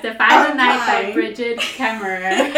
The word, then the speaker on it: Brigid Kemmerer: Kemmerer. – By Bridget Kemmerer, yes.